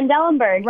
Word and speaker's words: Trendelenburg: Yes, Trendelenburg.